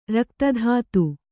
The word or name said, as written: ರಕ್ತಧಾತು